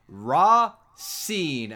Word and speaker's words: Racine: Uh, Racine, which I also wrote down for a couple of these characters how to pronounce some of their names. It is Ra Seen, as in you have seen her.